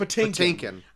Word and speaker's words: Patinkin: Patinkin.